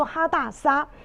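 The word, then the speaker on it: Hadassah: 但是呢，我们先来看一下，他原来的名字叫做哈大沙哈啊。哈大沙呢，我们来看一下希伯来文的发音叫做哈大沙。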